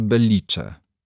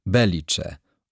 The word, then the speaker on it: Belice: Belice z akcentem nad i. Później, gdy nastąpiło trzęsienie ziemi, w ogólnokrajowej telewizji sycylijską gminę przemianowano na Belice. Owo charakterystyczne dla Rzymian cofnięcie akcentu nad e stało się synonimem pecha. Tak oto, utraciwszy pamięć, dolina straciła też swoją nazwę. Dzisiaj nawet Sycylijczycy, kiedy mówią o tej dolinie, nie kładą już akcentu we właściwym miejscu, na sposób arabski.